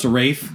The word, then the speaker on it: Rafe: Oh, but did he pronounce it like, No, it was pronounced Rafe. – But is it spelt Ralph? – No, it's pronounced R-A-F-E.